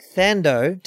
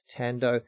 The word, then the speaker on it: Thando: It looks like Thando to the untrained eye, but it's pronounced Tando.